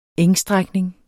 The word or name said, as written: engstrækning